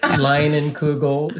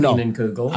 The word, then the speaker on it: Leinenkugel: Hey, don't ever mess up the German pronunciation, okay, Eric? Leinenkugel. It's not Leinenkugel.